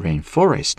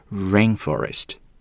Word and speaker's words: rainforest: 那么注意 rainforest 这个雨林单词的这个读音啊，重音呢是在前面的，不要读成 rainforest, no, rainforest。